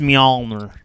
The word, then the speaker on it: Mjolnir: It seems like each of these amazing weapons is just a one-time thing, like Mjolnir, how do you say it? – No, right? – We do custom work. We do custom work. Okay. It's Mjolnir. – Okay. – Well, we in the South, we say Mjolnir. – Yeah. I was going to say, there's a bit of a twang there. – That's how we pronounce it.